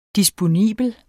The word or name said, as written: DISPONIBLE